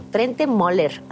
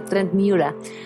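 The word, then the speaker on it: Trent Møller: Así que vámonos con esto de Trent Moller, les digo Trent Moller, que se supone que se pronuncia Trent Mueller,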